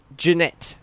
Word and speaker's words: Junaid: It's Junaid.